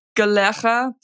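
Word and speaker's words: Kalerra: It's pronounced Galera, which is Greenlandic for the sound of it. It's old Greenlandic, and it's spelled Kalera. It's K-A-L-E-R-R-A, and it's all about being in the present moment, calming you down.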